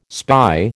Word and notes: spy: American English

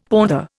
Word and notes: pother: Indian

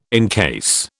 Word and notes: encase: British English

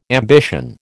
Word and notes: ambition: American English

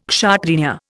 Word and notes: Kshatriya: Indian

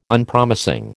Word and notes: Unpromising: American English

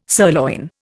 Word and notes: sirloin: Indian

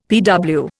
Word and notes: PW: Indian

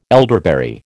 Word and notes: elderberry: American English

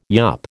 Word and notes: yup: American English

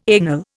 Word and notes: anal: Indian

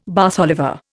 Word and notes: Bath Oliver: Indian

